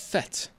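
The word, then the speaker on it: fête: Someone saying it's not feet, it's pronounced fet, but it's spelled fate.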